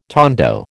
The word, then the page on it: tondo: American English